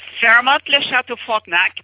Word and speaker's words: Fairmont Le Château Frontenac: Can you give us the correct pronunciation for us Americans that can't say it? – Fairmont Le Château Frontenac. That's exactly right.